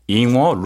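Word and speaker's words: 잉어 (硬卧): 잉저라는 등급과 그다음에 루안저, 네. – 잉어, 루안어. 제가 발음이 괜찮나요? – 네, 좋아요.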